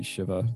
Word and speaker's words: Shiva: And so, like, you know, the very first time that you use, as they pronounce it, Shiva, it should be Shiva, that's actually how it's pronounced.